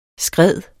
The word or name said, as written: skred